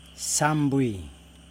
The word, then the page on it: sambwi: Yokoim